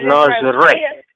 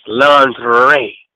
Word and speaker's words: lingerie: Almost said lingerie. – I mean, you know, that's the right way to say it. – Well, no, it's lingerie. It's lingerie.